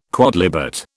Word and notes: quodlibet: British English

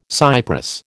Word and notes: cypress: American English

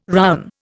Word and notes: rowen: Indian

